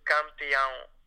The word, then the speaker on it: Campeão: Campeão.